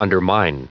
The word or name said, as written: undermine